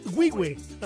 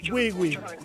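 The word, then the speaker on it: Güigüe: Compadre, ¿cómo se pronuncia bien? ¿Correcto ahí? Wiwi. Wiwi. Sí, vale.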